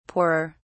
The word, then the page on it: poorer: British English